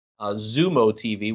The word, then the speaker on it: Xumo: Zumo TV, which is spelled X-U-M-O TV.